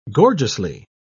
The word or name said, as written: gorgeously